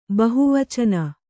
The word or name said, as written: ಬಹುವಚನ